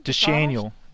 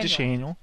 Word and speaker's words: Deschanel: With the old horse face, Sarah Jessica Parker, Zoe Deschanel, is that how you say her name? – Deschanel. Zoe Deschanel.